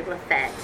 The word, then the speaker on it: Lafayette: or Lafayette. Did I say it right? – I bet they say Lafette. – Lafette, okay. – I still call it Lafette.